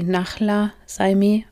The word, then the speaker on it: Nahla Saimeh: Ganz besonders hervorheben möchte ich hier Nala Saime. Ich glaube, korrekt wird sie Nachla Saime ausgesprochen. Eine absolute Koryphäe in dem Bereich der Kriminalpsychologie.